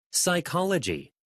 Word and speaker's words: psychology: Psychology. Psychology. She studied psychology. She studied psychology. – 여러분, 우리 수많은 유령 친구들 배웠지만요. P도 묵음 될수 있다는 거 기억해 주세요.